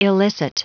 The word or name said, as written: illicit